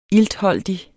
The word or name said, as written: iltholdig